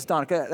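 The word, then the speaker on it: Danica McKellar: I think it's Donica. That's how you say her name.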